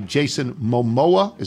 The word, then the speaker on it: Momoa: Jason Momoa. Is that how it's pronounced? Momoa? – Jason Momoa, yes, is Aquaman. – In the Aquaman films.